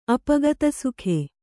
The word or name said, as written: ಅಪಗತಸುಖೆ